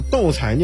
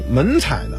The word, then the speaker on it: 斗彩: 斗彩也被人误称为斗彩。马未都曾经说过，把斗彩说成斗彩的是对斗彩知之不深，碰上这种人甭问，您就知道他刚入道。还听有人说呀，把斗彩念成门彩的，那是不认识这斗字的繁体字。